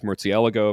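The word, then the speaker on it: Murciélago: At this point, like for years, I said Murcielago, and now it's Murcielago, of course, like the Spaniards finally corrected all of us, and someday I'll get to Palagos, but it doesn't even feel right leaving my mouth, so...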